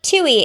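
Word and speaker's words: Twohy: No, it's like Tui. It's like the plant from Little Shop of Horrors.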